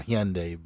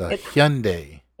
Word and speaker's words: Hyundai: It's definitely not a Honda. It's Hyundai. – It's Hyundai. – Hyundai. – Hyundai. – Hyundai. Yeah. You gotta say the teeth out. Hyundai.